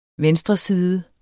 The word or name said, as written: venstreside